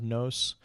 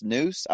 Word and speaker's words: nous: Like, just not explain, maybe just clarify. In the Aristotelian notion of, is it pronounced nous? I always pronounced it no, but yeah, it's Greek.